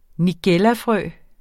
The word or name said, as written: nigellafrø